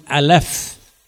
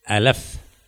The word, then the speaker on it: Aleph: So that in the pronunciation of that letter, just like we say A in English, A or B, it's called Aleph. Aleph. And Aleph is not to be pronounced, is to be only uttered in the spirit.